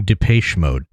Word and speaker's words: Depeche Mode: I'm going Depeche Mode today. Is Boy. What I've decided. Anyway. – I love how you pronounce Depeche Mode like Fraish.